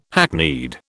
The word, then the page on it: hackneyed: British English